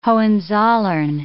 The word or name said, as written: Hohenzollern